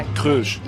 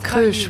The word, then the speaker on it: Crush: Crush. – It's just Crush, ouais. Ça se prononce comme ça s'écrit.